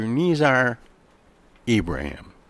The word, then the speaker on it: Nizar Ibrahim: And my guest today, Dr. Nizar Ibrahim.